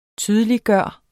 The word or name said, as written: TYDELIGGJORDES